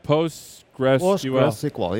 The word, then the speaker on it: PostgreSQL: Let's talk about PostgreSQL. Did I say it right? PostgreSQL, yes.